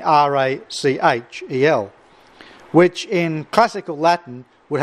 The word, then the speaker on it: Rachel: Uh, but in ecclesiastical Latin, when it comes before an, uh, an e, as it does here, the, the ch has a ch sound, as in uh, cherub and as uh, found in our English pronunciation of Rachel.